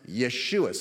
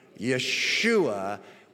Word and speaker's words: Yeshua: He heard Hebrew, where it's pronounced Yeshua. Say that with me. Yeshua, which is the exact same name as our English word transliterated as Joshua.